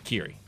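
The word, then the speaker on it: Kyrie: I know they have a song called Broken Wings, but this is Mr. Mister, and a great song called, is it Kiri. It was one of those 80s names that no one could ever pronounce right. So it's Mr. Mister, Kiri.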